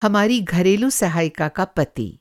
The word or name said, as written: सहायिका